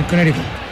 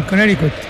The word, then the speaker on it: Connecticut: Ahí en Sherman, Connecticut. – ¿Cómo pronunció? Me gustó la pronunciación. – Sherman, Connecticut. – Esa.